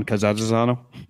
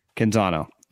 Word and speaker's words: Canzano: Well, I forwarded you the tweet about like John, how do you say the guy's name in Oregon that covers the Oregon Ducks? John Cazazzano? – Canzano.